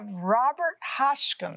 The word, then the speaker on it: Robert Hotchkin: And we have Robert Hotchkin, and I'm saying it right.